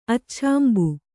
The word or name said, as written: ಅಚ್ಛಾಂಬು